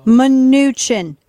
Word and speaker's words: Mnuchin: Munchkin said... – Mnuchin. She legit called that man Munchkin.